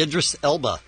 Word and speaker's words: Idris Elba: Mine would be Idris Elba.